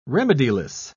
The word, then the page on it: remediless: British English